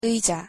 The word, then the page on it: 의자: Korean